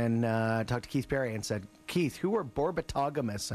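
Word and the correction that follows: Borbetomagus: I mispronounced their name.' And he goes, 'Oh, Borbeto Magus.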